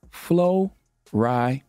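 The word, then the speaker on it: Florida: One word, two or three syllables, depending how you pronounce it. Flo-ri-da.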